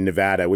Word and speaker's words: Nevada: If you really want to be of the people of the place, you have to say Nevada, which is super gringified.